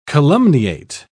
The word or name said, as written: calumniate